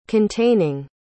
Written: containing

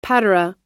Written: patera

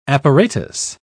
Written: apparatus

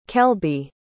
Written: kelby

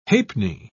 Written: ha'penny

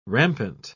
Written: rampant

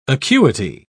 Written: acuity

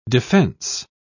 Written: defense

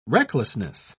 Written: recklessness

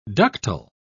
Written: ductile

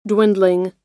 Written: dwindling

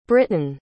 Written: britton

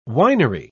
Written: winery

